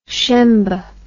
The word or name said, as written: shembe